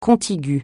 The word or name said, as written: contigu